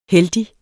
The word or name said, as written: heldig